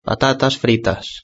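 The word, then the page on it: patatas fritas: Spanish (Spain)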